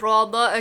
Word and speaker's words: Roda: It's Roda, okay.